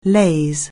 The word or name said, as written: laze